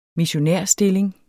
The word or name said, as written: missionærstilling